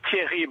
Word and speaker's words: Thierry: Yes, in French it's Thierry, but if you prefer, you can call me Jerry. It's easy for you.